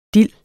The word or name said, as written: dild